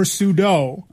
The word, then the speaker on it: sudo: Sudo or sudo. – Uh-huh. – I try to remember to pronounce it sudo because it's a super user do. – Okay. – Although, look, sudo as in P-S-E-U-D-O.